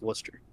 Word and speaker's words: Worcester: Yeah, technically with the double O, it should be Woo. – but it's Worcester.